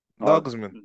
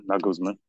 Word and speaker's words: Nagelsmann: Nagelsmann. – Nagelsmann.